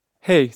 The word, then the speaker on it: He: Et tu l'avais prononcé correctement. Pas « e » ou « e » comme disent ceux qui ne me connaissent pas, mais « he » à l'anglaise, avec le « h » et les deux « e » qui font « he ».